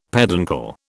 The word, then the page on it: peduncle: British English